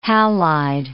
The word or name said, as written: halide